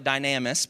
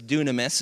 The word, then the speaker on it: dunamis: It's the Greek word dunamis, and it looks like dynamis, but it's dunamis is how you pronounce it.